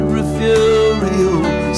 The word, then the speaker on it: Refugio: Pronounce it Refugio.